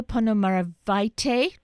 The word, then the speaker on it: Ponomariovaite: Inesa Ponomariovaite, oh, did I say that correctly? – Yes.